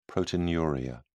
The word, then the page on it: proteinuria: British English